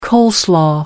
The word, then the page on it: coleslaw: American English